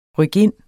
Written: RYKINDETS

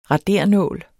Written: RADERES

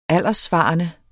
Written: alderssvarende

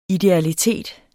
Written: idealitet

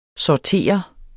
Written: sorterer

